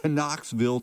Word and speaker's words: Knoxville: There's a silent K there, folks. I'm just letting you know. I do that for effect. Knoxville, Tennessee.